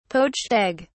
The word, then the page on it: poached egg: British English